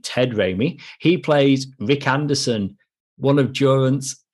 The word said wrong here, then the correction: Durant: Jorunt or Durant? One of the two. – Durant. It's going to be Durant. – That sounds better.